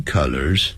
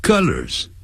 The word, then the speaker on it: colors: 所以 this T-shirt comes in three colors，哎，颜色不要念成 colors，不是啊的音，是呃的音，肚子呃的呃 colors colors，那是 C-O-L-L-A-R 加个 S，这是代表多数，要衣领领子。